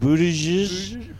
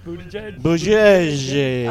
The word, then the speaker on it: Buttigieg: What's the way his name is supposed to be pronounced? Buttigieg? – Buttigieg. – Oh, yeah, Buttigieg.